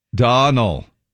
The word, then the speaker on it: Domhnall: Donal. Like tonal or tonal? – It's donal. – Donal? – Rhymes with tonal. – Donal Gleeson. Where's the M? – M? – Where's the M? You spell his name. D-O-M. – You don't say it. D-O-M-H-N-A-L-L, but it's donal. – The M and the H are silent.